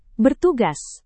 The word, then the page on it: bertugas: indonesian